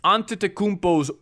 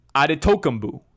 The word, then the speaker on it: Antetokounmpo: Now, it's spelled Antetokounmpo.